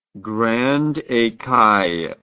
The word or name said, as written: grande ecaille